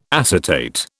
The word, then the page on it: acetate: British English